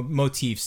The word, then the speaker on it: motif: And then there's kind of what he's referring to kind of as a regal motive, which, you know, motif, sorry, I'm mispronouncing that.